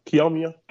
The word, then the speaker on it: Kiyomiya: Is that correct pronunciation? – Yep, pretty good. Close enough. Kiyomiya. Yeah.